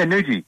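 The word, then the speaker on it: Noojee: Is that how you say it? – Yeah, Noogee. – Noogee? – Yep. They call it the Nooge.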